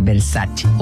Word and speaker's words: Versace: Por ejemplo, Versace, cuando la marca Versace es italiana, entonces tendré que pronunciar Versace. O